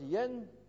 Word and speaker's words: Jan: J-A-N, pronounced Yen, is the Czechoslovak or Bohemian part, counterpart of John.